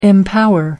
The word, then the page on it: empower: American English